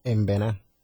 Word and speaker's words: Embera: How do we say? ¿Cómo se dice? – Embera. – There you go. Embera. – Embera. – A little bit of nasal in their language.